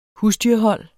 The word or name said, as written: husdyrhold